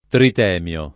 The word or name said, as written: Tritemio